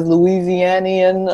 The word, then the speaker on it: Louisianian: Louisianian of the Year. Did I say it right? – You did.